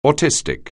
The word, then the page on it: autistic: anglais